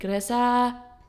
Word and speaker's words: Gresha: Okay, my name is Gresha. But in Nepali, you would pronounce it Gresha. I don't know. It's like Gresha.